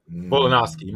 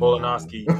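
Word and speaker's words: Volkanovski: And it would be him fighting Volachansky. I think it's Volachansky is how you pronounce it. That may be my bad. Or Khabib. One of those two men. Volanovski, my bad. Volanovski or Khabib.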